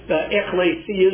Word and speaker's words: ekklesia: Now, the Greek word you can see is ekklesia, is the way that is pronounced,